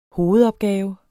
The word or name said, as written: hovedopgave